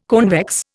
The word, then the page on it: convex: Indian